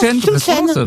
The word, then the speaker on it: chouchen: Comme ça se prononce, chouchen.